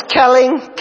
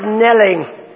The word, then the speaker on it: knelling: Now, I thought it was Kelling, K-N-E-L-L. But it's pronounced knelling.